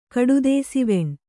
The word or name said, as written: ಕಡುದೇಸಿವೆಣ್